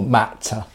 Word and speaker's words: Matt: I do um, pronounce my T's more, Matta. Matta. Because sometimes people think I say Max, and then it's this whole thing.